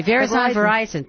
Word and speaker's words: Verizon: Is it Verizon? I'm sorry. Verizon. Thank you. Rhymes with horizon.